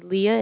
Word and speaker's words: Leah: My name is Leah, and that's spelled L-E-A-H.